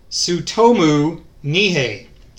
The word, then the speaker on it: Tsutomu Nihei: Tsutomu Nihei, and he wrote several very popular manga, and the first one we'll be talking about is Knights of Sidonia.